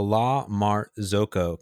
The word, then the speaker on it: La Marzocco: There's a La Marzocca. How do you spell it? La Marzocca. This is the Italian, okay. Florence-based Italian espresso maker. La Marzocco.